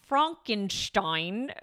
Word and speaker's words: Frankenstein: So therefore, Frankenstein, I don't know if I'm pronouncing anything right. – No, you're nailing it.